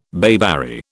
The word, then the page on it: bayberry: British English